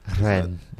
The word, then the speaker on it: Rennes: That's not how you say it. – Rennes. – There we go. Roll your R's.